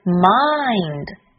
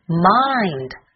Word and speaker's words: mind: 如果延长这个鼻音，发出来的效果应该是 mind mind。另外个别小朋友把末尾的 d 发音发成了汉语里的的，这样就不对了，它是的，而且要弱化 mind mind never mind。